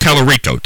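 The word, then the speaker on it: Talarico: I think Tallarico. Tallarico.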